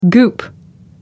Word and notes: goop: American English

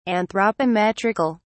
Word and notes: anthropometrical: British English

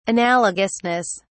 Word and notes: analogousness: British English

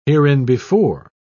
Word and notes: hereinbefore: British English

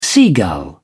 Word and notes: seagull: British English

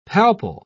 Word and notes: palpal: British English

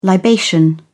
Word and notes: libation: British English

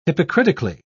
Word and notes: hypocritically: British English

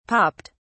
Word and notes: popped: British English

